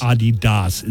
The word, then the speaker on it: Adidas: Adidas is how it's supposed to be pronounced.